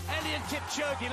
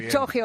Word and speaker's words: Eliud Kipchoge: Bueno, Eliud Kipchoge, que así lo pronuncian de bien, no como nosotros que decimos muy Kipchoge bien. O lo que nos pase por la cabeza, ha corrido más que una liebre.